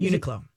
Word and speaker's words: Uniqlo: Uniqlo.